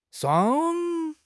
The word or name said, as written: shuang1